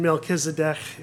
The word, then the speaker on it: Melchizedek: The CH in Melchizedek has the sound, like Bach. It's Melchizedek. I can't do it.